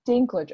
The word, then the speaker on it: Dinklage: Dinklage. Okay, so I totally said his name wrong. – I said might Dinkle.